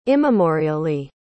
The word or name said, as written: immemorially